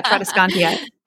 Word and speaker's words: Tradescantia: You said it right the first time. Yeah, Tradescantia.